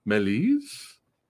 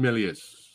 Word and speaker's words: Méliès: Pronounced Melis. I'm gonna stick with Méliès.